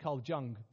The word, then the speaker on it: Jung: And one of the guys you study in psychology is Carl, I pronounce it Carl Jung, but I'm sure it's the J is silent and there's all sorts of German bits going on.